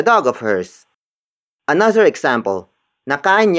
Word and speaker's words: nakain: Nakain niya ang tanghalian. Nakain niya ang tanghalian. The first word is nakain. Nakain. Nakain. Nakain is spelled as N-A-K-A-I-N.